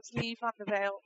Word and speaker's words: Leigh Van Der Byl: and just for anyone listening, it's pronounced Leigh Van Der Byl.